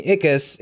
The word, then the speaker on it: Ickis: And he's well known by us for voicing Ickis in the show Ah, Real Monsters.